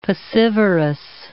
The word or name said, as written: piscivorous